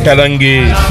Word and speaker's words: Kalangé: Kalangé, avec bien sûr Aliva. Toujours du mal à prononcer son nom. Aliva et son orchestre. Kalangé, très bien dit DJM.